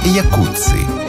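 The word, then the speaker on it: Jacuzzi: Это и есть то, что мы называем словом «джакузи». Хотя правильнее было бы сказать «якуци». Именно так в действительности произносится фамилия тех самых братьев-итальянцев.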